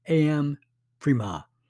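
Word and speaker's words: Aham Prema: This mantra is pronounced Aham Prima.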